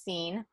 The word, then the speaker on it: SENE: We've been accepted into, it's an acronym, S-E-N-E, it's pronounced SCENE.